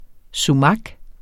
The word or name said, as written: sumak